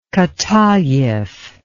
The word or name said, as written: katayev